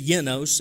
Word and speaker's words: genos: Now, Peter continues, and he uses that word race there, and the Greek word there, which we would say genos, truly it's genos, if you pronounce it correctly.